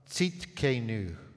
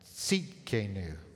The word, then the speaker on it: Tzidkenu: Tonight we're going to be looking at a name of God that's a little hard to pronounce. It's Jehovah Tzidkenu. Try that. Tzidkenu.